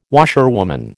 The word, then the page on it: washerwoman: American English